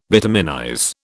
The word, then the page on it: bituminize: British English